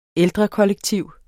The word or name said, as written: ældrekollektiv